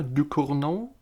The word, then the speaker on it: Ducournau: Wir reden über den zweiten Film der Regisseurin Julia Ducournau.